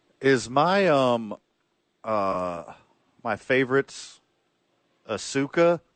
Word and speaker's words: Asuka: And Van, Tampa is ready for Asuka.